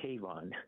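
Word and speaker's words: Kavon: No, it's K-A-V-O-N, like Avon with a K.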